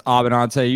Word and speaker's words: Abbenante: It's Abbenante. You you got a 98% right.